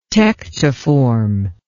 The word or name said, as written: tectiform